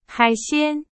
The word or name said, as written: 海鲜